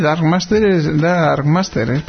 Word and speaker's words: Dark Master: The Dark Master. – Sí, algo así, ¿no? Sí. ¿Lo he dicho bien? – Sí, Dark Master, pues está claro, el maestro oscuro, o sea que usará técnicas oscuras.